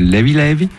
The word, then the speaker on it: Levé Levé: Alors volume 2 donc de Levé Levé, qui se prononce là-bas Levi Levi, ça veut dire léger léger, tranquillou un peu, c'est, en gros c'est, il n'y a pas de stress.